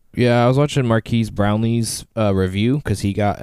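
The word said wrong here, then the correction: Marques: Or did I say it right? – It was Marquez.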